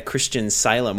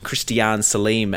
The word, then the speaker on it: Christian Salem: What about a Christian Salem, or a Christian Salim, as JB pronounced it that time, that I'll never let him live down.